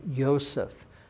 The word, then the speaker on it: Yosef: I know it's Joseph, but remember, Be'evrit, in Hebrew, you don't have the J sound. It's pronounced as a Y sound. So Yosef is how you pronounce the Hebrew name in Hebrew, Joseph.